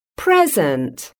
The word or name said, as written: present